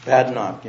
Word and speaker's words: Badnock: Tell me how you say your name. – Badnock, Your Honor. – Badnock. – Just like got it. Badnock, please proceed.